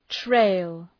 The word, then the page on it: trail: treıl